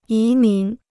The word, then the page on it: 移民: yí mín